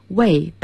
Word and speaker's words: weigh: Weigh something for somebody, 给某人称重，代表动词 weigh, W-E-I-G-H, 它的名词叫 wait, 听起来有点像等待 wait, 发音是一模一样的，但是要注意一下你表达的意思。